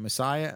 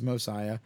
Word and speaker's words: Mosiah: Messiah. It's not Messiah. It's Mosiah. Book of Alma, Helaman, 3rd Nephi, 4th Nephi, the book of Ether, not Esther, Ether, and the book of Moroni.